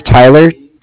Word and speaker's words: Tyler: My name is Tyler. T-Y-L-E-R, mm-hmm. And I'm calling with Cardiff. It's spelled C-A-R-D-I-F-F, as in Frank Frank.